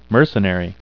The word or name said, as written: mercenary